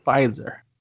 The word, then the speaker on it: Pfizer: Because the P is silent. The silent P. Pfizer.